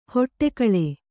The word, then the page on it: ಹೊಡೆಗುಳು: kannada